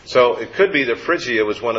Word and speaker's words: Phrygia: Am I pronouncing it? Phrygia? – Yeah.